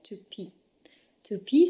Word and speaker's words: Toopi: La troisième et dernière green tech dont je vais vous parler, elle s'appelle To To pee, ça s'écrit T-O-O-P-I. Bien sûr, ça vient de l'anglais to Tupi, it comes from the English to pee, but it's written differently.